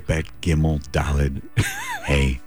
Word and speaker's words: Dalet: So just to give you an example, the number 4 in Hebrew is the letter Dalet, is how you say it. Obviously, it sounds like a D, and it is.